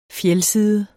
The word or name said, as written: fjeldside